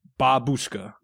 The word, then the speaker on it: babushka: Now I know it's Babushka. – Yeah, well, Babushka. – Babushka. – Babushka. Yeah. The emphasis was on the wrong syllable.